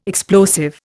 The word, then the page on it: explosive: Indian